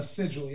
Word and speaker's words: sigil: Okay, and tell me how to pronounce this. Is this a sigil? – A sigil, yes.